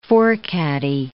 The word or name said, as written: forecaddie